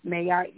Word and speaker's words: Maat: My name is Mayat Yeboah. It's spelled M-A-A-T. Last name is spelled Y-E-B-O-A-H.